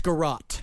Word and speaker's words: garrote: how is this pronounced, garrote? – It's garrote.